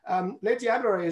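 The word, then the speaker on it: Les Diablerets: Now, we are actually, Le Diablerey is actually just up in the mountains above the seat of WBCSD, your new gig, isn't that right? – Yes, and well done for pronouncing it properly.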